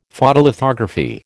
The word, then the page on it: Photolithography: American English